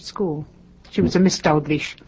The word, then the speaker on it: Dalgleish: Now, most of us refer to the commander as Dalgleish. – That's that correct? – Absolutely correct. – Scottish? – It is Scottish.